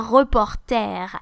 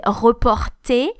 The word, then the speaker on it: reporter: So it's uh, un reporter, which means a reporter, like a, a, a journalist um, you have to notice that we actually pronounce it a bit like um, the English way, à l'anglaise, un reporter.